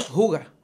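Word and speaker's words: hygge: Juga. – Se escribe H-Y-G-G-E. H-Y-G-G-E. – Y se. Eh, eh, juga.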